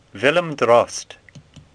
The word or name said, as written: Drost